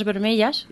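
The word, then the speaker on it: vermelles: Una de ellas es pulseras rojas, pulseras vermellas. ¿Lo he dicho bien? – Sí, más o menos. Vermellas, sí.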